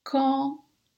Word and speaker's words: quand: elle a fini, quand elle a fini, quand, quand avec un D à la fin, mais quand on fait la liaison, ce D se prononce T.